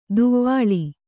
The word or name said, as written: ದವಳ